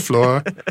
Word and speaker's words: Flor: That was floor. F-L-O-R, is that how you say it? – Yes.